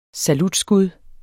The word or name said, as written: salutskud